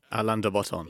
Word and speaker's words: Alain de Botton: so will you say it for me? – Alain de Botton.